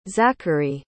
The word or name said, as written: zachary